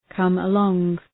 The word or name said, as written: come along